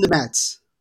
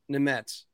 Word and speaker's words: Nemec: Say Simone's name for me. It's Simone Nemets. – Nemets. Okay, good. Because I need to make sure I get that down because I see Nemec, and just don't want to mispronounce it.